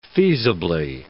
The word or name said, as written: feasibly